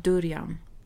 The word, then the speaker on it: durian: Alltså jag har noll koll och jag vet inte ens hur man uttalar det. Kan du se vad det står här? En durian kanske? Ja, durian. Skulle jag också vilja säga.